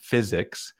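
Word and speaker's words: Fizzics: It's called Physics, and it's spelled like F-I-Z-Z, Physics.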